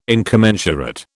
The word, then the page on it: incommensurate: British English